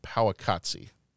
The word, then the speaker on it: Powaqqatsi: Powakatsi. And I think I might be P-O-W-A-Q-Q-A-T-S-I is how it's spelled.